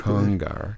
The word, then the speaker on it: Congar: How'd I do with the pronunciation of his last name? – I think you did pretty good. – Congar. – Yeah, I think that's pretty much it.